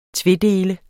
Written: tvedele

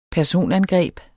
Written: personangreb

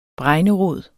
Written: bregnerod

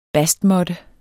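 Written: BASTMÅTTE

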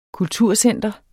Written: kulturcenter

